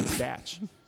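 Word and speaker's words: Stach: Is his last name Statch? – It's S-T-A-C-H. How else would you pronounce that? Benjamin Statch.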